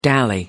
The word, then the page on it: dally: American English